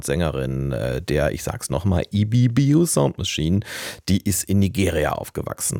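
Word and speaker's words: Ibibio Sound Machine: Nicht, dass ich EBBO Sound Machine das erste Mal richtig ausgesprochen habe, wobei das könnte auch eine Premiere gewesen sein, sondern dass wir diesen Song gespielt haben, Love.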